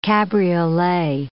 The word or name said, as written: cabriolet